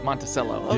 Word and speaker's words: Monticello: Monticello.